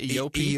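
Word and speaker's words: Eopie: Is that how you pronounce it? Yopi? – Yopi, I believe.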